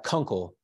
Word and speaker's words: Kunkel: Kunkel. So Kunkel. – Yeah, but it is German, so you probably pronounced it a little bit better.